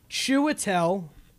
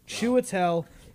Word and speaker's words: Chiwetel: It's Chiwetel. That's really how you pronounce his first name. Gesundheit. Chiwetel.